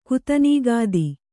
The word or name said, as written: ಕುತನೀಗಾದಿ